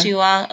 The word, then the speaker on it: Shuhua: I thought it was Shuwa, but... – Shua. – Okay, that sounds more correct.